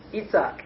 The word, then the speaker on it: Yitzhak: Either way, how would you say that name if it was applied to somebody today, a Jew in Israel? What's that? Yitzhak. Yitzhak Rabin is Isaac Rabin.